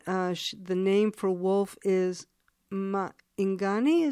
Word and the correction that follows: Maingan: is that right? – Yeah, Maingan, yeah.